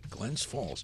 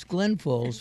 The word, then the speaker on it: Glens Falls: Oh, you said it right, Glens Falls. – Well, it's Glens Falls for some. It's Glen Falls for the rest of us.